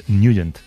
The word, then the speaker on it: Nugent: Hombre, siempre lo hemos llamado en el barrio Ted Nugent o Ted Nugent. – Claro, ¿verdad? Yo era de Ted Nugent. – Pero la pronunciación correcta es Nugent.